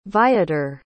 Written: viator

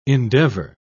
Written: endeavour

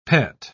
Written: pet

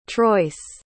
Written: troyce

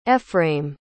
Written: efraim

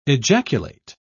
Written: ejaculate

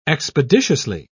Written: expeditiously

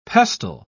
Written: pestle

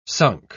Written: sunk

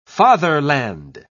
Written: fatherland

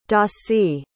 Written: dossie

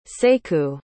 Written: sekou

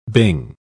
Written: bing

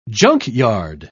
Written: junkyard

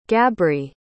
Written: gabrie

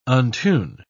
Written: untune

